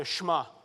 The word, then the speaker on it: Shema: Now, depending on what part of the country you're from, you might call it the Shema or the Shema, right? But it's actually pronounced the Shema.